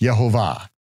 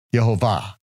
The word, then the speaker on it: Yehovah: And in that Aleppo Codex, with all three vowel pointings, then Nehemiah Gordon then says, okay, now we know how to pronounce it, because the vowel pointings are not the vowel pointings for Adonai, that's a tradition. No, it would be pronounced Yehovah, Yehovah, Yehovah.